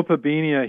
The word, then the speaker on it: Opabinia: I don't know how to say it. – Oh, Opabinia.